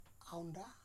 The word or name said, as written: Aunda